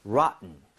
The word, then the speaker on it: rotten: rotten보다 사실은 rotten처럼 T 사운드가 굉장히 약화되거든요. – 네. – 한번 이거 발음해 보도록 하죠. Okay? rotten. rotten.